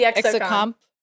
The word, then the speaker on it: exocomp: The exocomp. Exocomp. – Like, as I say it, I forget the third syllable. I know XO, and then I'm just like, con? Okay, comp.